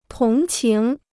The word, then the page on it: 同情: tóng qíng